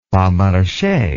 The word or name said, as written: pas marche